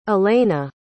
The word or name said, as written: allena